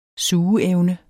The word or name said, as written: sugeevne